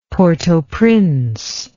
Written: port au prince

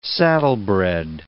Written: saddlebred

